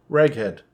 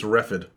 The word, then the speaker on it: Reghed: Reghed is spelled R E G H E D. Raghead. Yeah. Not the other thing.